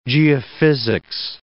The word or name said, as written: geophysics